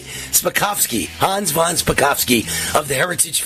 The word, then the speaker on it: Hans von Spakovsky: Hans von Spakovsky.